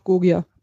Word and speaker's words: Gogia: Gotcha wird er wahrscheinlich ausgesprochen, oder? Go nee, ja. ganz einfach Gogia.